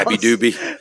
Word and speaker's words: Abu Dhabi: I'm pretty sure it just has to do with, because this is taking place at the Fight Island in Abu Dhabi, which I always mispronounce. – Abu Dhabi.